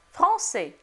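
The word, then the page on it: français: language French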